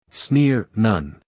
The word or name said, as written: sneer